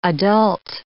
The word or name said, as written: adult